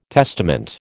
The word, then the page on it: testament: American English